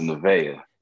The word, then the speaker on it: Nevaeh: Yeah, so it's pronounced Nevaeh. – Nevaeh, got you.